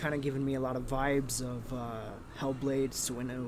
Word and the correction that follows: Senua's: Senua's Sacrifice.